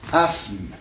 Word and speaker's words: asthme: À tel point que dans les textes, vous trouvez, au lieu de I-S-M-E, vous trouvez I-Z-M-E. M-E. Voyez-vous? Prenons un mot qui indique une maladie respiratoire. – Asthme. – Hein? Donc, il a aussi, n'est-ce pas? S-M, n'est-ce pas? Faisons, écartons le TH de l'orthographe, n'est-ce pas? Qui ne se prononce pas.